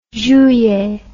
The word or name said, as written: juillet